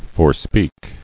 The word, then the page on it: Forespeaking: fore·speak